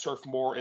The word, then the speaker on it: Turf Moor: We're going to be playing at Turf Moor in Lancashire. Hope I said that right. It's going to be on Wednesday. Catherine said I said it correctly. Thank you.